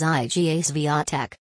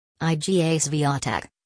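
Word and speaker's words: Iga Świątek: With 205,791 views on Saturday, October 10, 2020, our article of the day is IGA Swiatek. IGA Swiatek, Polish pronunciation, Jacek Jantek, born May 31, 2001, is a Polish professional tennis player.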